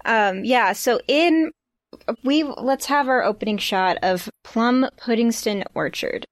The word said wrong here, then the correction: Plum Puddingstone: You said it wrong. – Plum Puddingstone Orchard, because this place, the name is Plum Puddingstone Orchard, aka there's like a bunch of words happening here. Unlike Puddingston, we say the stone here for this one.